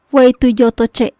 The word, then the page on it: Wey tu yotoche': Maya